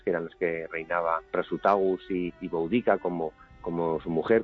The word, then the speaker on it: Prasutagus: con Prasutagus.